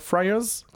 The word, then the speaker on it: Friars: et Hippolyte Bourdet qui campe une multitude de rôles, dont celui de Mr. Friars, comme ça qu'on prononce Oui. Exactement, beaucoup, plus euh, euh, concis.